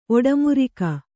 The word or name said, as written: ಒಟ್ಟೆಮೃಗ